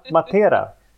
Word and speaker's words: Matera: Nu är det Matera. – Ja, precis. – Ja, okej. Okay. Nu var det den andra. Nu var det betoning på andra vokalen.